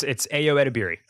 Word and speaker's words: Ayo Edebiri: It's Ayo Edebiri.